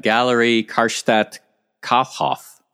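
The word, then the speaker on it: Galeria Karstadt Kaufhof: Gallery Karstadt Kaufhof is one of Europe's largest... – Wunderbar. That was a great pronunciation.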